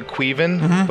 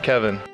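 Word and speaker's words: Caoimhín: There's a word that looks like it should say Queven, but it's pronounced Kevin.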